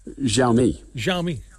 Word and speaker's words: Xiaomi: How do I say it? – Xiaomi.